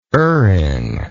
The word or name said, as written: erring